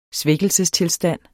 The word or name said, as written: svækkelsestilstand